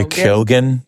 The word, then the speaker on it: Keoghan: Barry Keoghan.